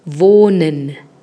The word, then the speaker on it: Wohnen: Wohnen.